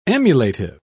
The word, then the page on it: emulative: British English